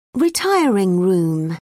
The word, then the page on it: retiring room: British English